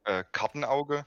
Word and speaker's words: Kartenauge: I know German is hard to pronounce for people not German. My name is called Kartenauge. It's like in English would be like card eye.